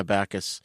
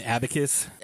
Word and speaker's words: abacus: Now, the best one I thought was the bookkeeper, because I don't think I've ever seen death by an abacus in my life in a movie. – An abacus? – Abacus. Sorry, I keep pronouncing it wrong. – You put the emphasis on the wrong syllable.